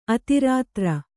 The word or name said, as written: ಅತಿರಾತ್ರ